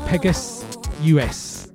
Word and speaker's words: PEGASVS: It's pronounced Pegas US, but spelled P-E-G-A-S-V-S.